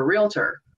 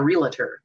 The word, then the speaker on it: realtor: And when you sell homes, you're a realtor, not a realtor. There's not three syllables in it.